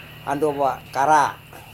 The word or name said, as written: andowa-kara